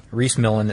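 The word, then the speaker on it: Rhys Millen: Reese Millen, it's spelled R-H-Y-S, and then Millen is M-I-L-L-E-N, and the one that we're talking about is where Reese Millen, it says Reese Millen smashes the Pikes Peak record.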